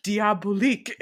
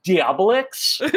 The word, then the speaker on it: Diabolique: so diabolique? Like, you mean it's not pronounced diabolix?